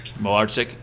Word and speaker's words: Malartsik: Did I say that right, Pat? – Malartsik.